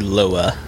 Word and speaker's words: Loa: The world's largest active volcano is Mauna Loa in Hawaii. I'm sure that's not how you say it. – I think it's Loa, thank probably. You. – Yeah. – In Hawaiian, you pronounce all the vowels.